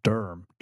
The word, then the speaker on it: Durham: Durham, it's pronounced with, there's just one syllable.